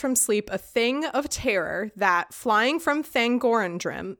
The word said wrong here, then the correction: Thangorodrim: Th- Thangorodrim, I could never say that right, but that Thangorandrim was essentially Morgoth's or Melkor's evil fortress in the Silmarillion.